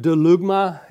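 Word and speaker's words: delugma (βδέλυγμα): It's a Greek word, delugma. It actually begins with the B. The B is silent. Don't try to say it with the B, okay?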